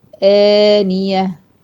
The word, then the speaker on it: آنية: تسقى من عين آنية، شايفها آنية، أنطقها إزاي؟ آنية، حركتين. طب أنا إزاي أفرق؟